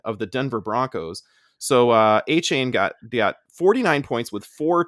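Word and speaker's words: Achane: And then he also has this Akani. – A-chain. – Is that how you say it? Akani? – Devon A-chain. – Oh, A-chain.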